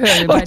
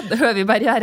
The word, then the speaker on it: Høviberget: Så det mast som ICE har bygd nå, og satt opp på skistadion ved Varden på toppen av Høviberget, har jeg fått innspill på at det uttales. – Det stemmer, Høviberget. – Det rett? – Ja, Høviberget rett.